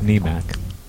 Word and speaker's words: NMAC: And it's generally pronounced NEMAC.